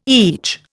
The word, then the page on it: each: Indian